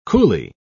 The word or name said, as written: coolie